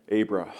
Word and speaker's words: Abraham: And see, in the Hebrew, the suffix is not pronounced. It's just a rush of air. Abraham.